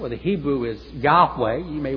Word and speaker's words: Yahweh: Now, they may say, well, you may want to pronounce it in Hebrew, Yahweh, which is probably more accurate, by the way, Yahweh or Yahweh.